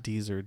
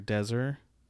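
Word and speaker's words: Dezer: But I was reading on Orlando Weekly that Michael Deser, I think you pronounce it Deser. Not Deezer, Deser.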